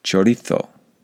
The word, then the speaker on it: chorizo: If we try to pronounce these French words like the French, then we should try to pronounce the Spanish words that are in the English language like the Spanish. So we should say paella. We should say chorizo.